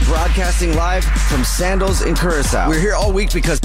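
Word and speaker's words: Curaçao: How do you say it again? What was the Curacao? – Curacao, that's beautiful. That's I'm going to try to say it that way.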